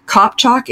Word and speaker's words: Kopcok: So Darina, D-A-R-I-N-A, Kopchok is spelled K-O-P-C-O-K. So Darina Kopchok, one word, .com.